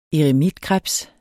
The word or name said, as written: eremitkrebs